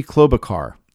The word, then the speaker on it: Klobuchar: I never say her name right. Amy Klobuchar. There we go. I think I said it better that time.